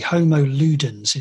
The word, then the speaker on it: Homo Ludens: There's a chapter in the book, and I'm not even sure if I'm pronouncing the title of it correctly. Homo Ludens, is that right? – Yes.